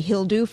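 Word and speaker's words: Hildur: But he had told me that as an American who can't do an accent for the life of me, I can say Hildu for her first name.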